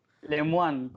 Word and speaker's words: Lemoine: ¿Por ahí cómo se pronuncia, Cristóbal? – Lemoine, con, con una N al final.